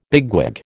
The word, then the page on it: bigwig: American English